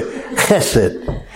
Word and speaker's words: chesed: It's kind of like you clear your throat at the beginning of it when you say chesed. And it's, if you're going to spell it in English, it'd be C-H-E-S-E-D.